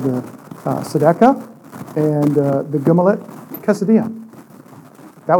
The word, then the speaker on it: tzedakah: Let me spell it for you. You ready? They're T-Z-E-D-A-K-A-H. The tzedakah.